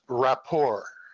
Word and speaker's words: rapport: Well, first of all, I don't want to seem nitpicky, but the proper pronunciation is rapport.